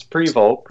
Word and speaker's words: Prevost: It's pretty close. It's Prevost. Pretty close.